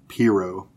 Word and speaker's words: Pirro: Her name is Jeanine Pirro.